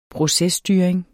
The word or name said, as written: PROCESSTYRING